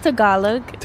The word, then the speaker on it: Tagalog: So do you speak, is it Tagalog? – It's pronounced Tagalog.